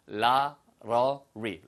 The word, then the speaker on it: lottery: 발음은 로터리라고 안 들리고요. lottery, lottery처럼 들립니다.